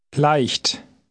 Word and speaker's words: leicht: It contains the letter combination EI. This is pronounced I. I. – Try reading word 16 out loud. – Leicht.